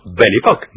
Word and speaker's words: Belle Époque: Y en francés no es Belle Époque, o no sé cómo se dice en portugués, casi. No es Belle Époque, sino Belle Époque.